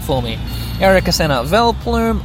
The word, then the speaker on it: Vileplume: I've been pronouncing it wrong all my life. I've just read it with an adult's eyes for the first time, this Pokemon's name. It's Vileplume, not Vileplume.